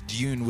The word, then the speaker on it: Dune: You know, back in the day, the way I would pronounce Dune would be with a Y. Dune. Dune. Although it seems like an unnecessary syllable. Is that a syllable? Dune. I feel that turns it into a two-syllable word. Adding the Y.